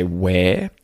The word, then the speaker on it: where: And even though where, when it's said not followed by a vowel. It's said without a consonant sound at the end. So you just say where. – There's no R sound.